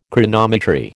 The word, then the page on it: Chronometry: American English